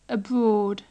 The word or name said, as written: abroad